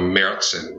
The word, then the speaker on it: März: So officially it's called a Märzen, right? So in Germany, March is pronounced März.